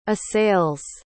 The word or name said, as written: assails